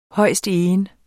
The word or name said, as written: højstegen